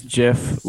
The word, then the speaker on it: GIF: So it, for me, it's GIF, but you know, I honestly think we're all in agreement right here that it is GIF with the with a soft G.